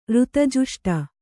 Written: ಋತಜುಷ್ಟ

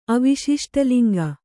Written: ಅವಿಶಿಷ್ಟಲಿಂಗ

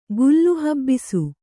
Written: ಗುಲ್ಲುಹಬ್ಬಿಸು

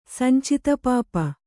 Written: ಸಂಚಿತಪಾಪ

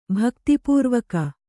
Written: ಭಕ್ತಿಪೂರ್ವಕ

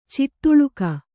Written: ಚಡಾಳಿಕೆ

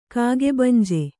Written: ಕಾಗೆಬಂಜೆ

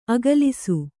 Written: ಆಕ್ಲಾಸ

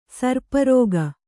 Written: ಸರ್ಪರೋಗಿ

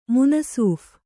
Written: ಮುನಸೀಫ

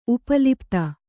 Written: ಉಪಲಿಪ್ತ

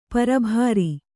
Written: ಪೆರ್ಬರಿ

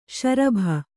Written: ಷರಾಬು

